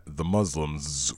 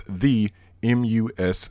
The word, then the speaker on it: The Muslims: They're on Instagram at The Muslims with the Z sound at the end. That's The Muslims, The Muslims.